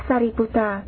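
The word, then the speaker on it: Sāriputta: Ngài Xá Lợi Phất, Ấn Độ là Sariputta, chữ Hán là Thân Tử.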